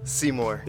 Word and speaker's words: Seymour: Seymour.